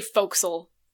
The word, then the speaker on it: forecastle: Hang on, let me see how you actually pronounce it. – Foxel. Foxel. – I think it's... Okay, it's actually Foxel.